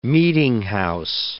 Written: meetinghouse

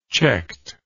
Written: checked

